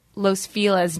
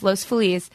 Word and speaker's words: Los Feliz: I mean, they're in the placid, serene hills of Los Feliz, which is the correct way to say it. In L.A., people say Los Feliz now, but... It's Los Feliz.